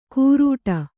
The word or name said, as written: ಕುರುಡ